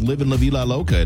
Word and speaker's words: Livin' La Vida Loca: I woke up with Livin' La Vida Loca in my head. Is that how you pronounce it? – Yeah.